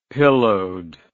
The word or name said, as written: pillowed